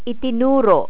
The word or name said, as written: itinuro